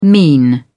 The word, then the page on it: mean: British English